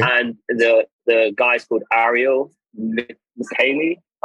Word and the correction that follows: Michaeli: It's actually in Hebrew, so I can say his name in Hebrew. It's Ariel Michaeli.